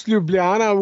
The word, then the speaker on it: Ljubljana: Well, you pronounce Ljubljana, which is not easy, uh, pretty much perfectly.